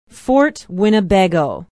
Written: Fort Winnebago

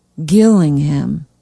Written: Gillingham

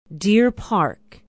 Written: deer park

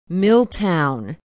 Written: milltown

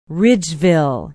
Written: ridgeville

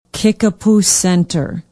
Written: kickapoo center

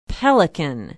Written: pelican

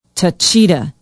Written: taycheedah

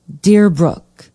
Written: deerbrook

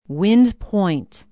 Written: wind point

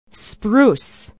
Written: spruce

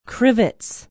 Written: crivitz

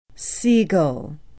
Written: sigel

